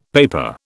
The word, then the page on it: paper: British English